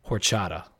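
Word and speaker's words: Orxata: It's O R X A T A, and I believe it's pronounced horchata, like the uh, like the milky drink.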